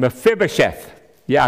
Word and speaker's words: Mephibosheth: It's the story of a king, King David, and Mephibosheth. Yeah, I practiced that, so I know how to say it.